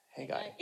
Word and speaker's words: Haggai: So yeah, hey hey guy, guy, hey guy. I'm saying it right, right? Sometimes it sounds weird.